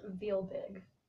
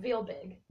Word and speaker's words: RealBig: Like, I'm always butchering your last name. How's it pronounced? – It's pronounced RealBig. – RealBig? – RealBig. – Okay, see, I was always calling it Berg for some reason.